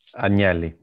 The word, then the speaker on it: Agnelli: And your chairman, Agnelli, if I said that correctly? – Agnelli, yeah.